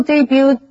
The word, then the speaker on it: debut: 这位女演员22岁的时候在伦敦的一出音乐剧中首次登台。或者是 The company will debut this new product in May. 这家公司会在5月份推出这个新产品。Debut 也可以当名词，例如 The band's debut album was a great hit.